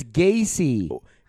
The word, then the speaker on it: Gaethje: How do you say his last name? – It's Gaethje. – Then Justin Gaethje. – It should be G-A-C-Y then. – That's... – like John Wayne Gaethje.